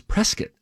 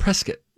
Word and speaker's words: Prescott: It is Prescott. – Oh, Prescott. – Pronounce it Prescott.